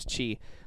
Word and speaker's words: Chi: But her name is Chi, which rhymes with she.